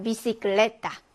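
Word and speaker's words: bicicleta: 비시클레타 해서 역시 번데기 발음 혹은 S 발음이 됩니다.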